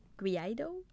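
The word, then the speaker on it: Guaidó: In other news, Juan Guaido, I don't know if that's, I'm saying it right. Guaido? – It's fine.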